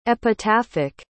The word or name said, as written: epitaphic